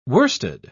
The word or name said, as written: worsted